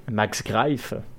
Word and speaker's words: Max Greif: Tu nous reprononces ça comme du monde? – Max Greif. – Wow. C'était étonnant.